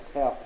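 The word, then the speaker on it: teh (τε): There's a thing called a particle in Greek grammar T-E is the way it's spelled, the teh particle.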